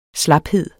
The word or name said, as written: slaphed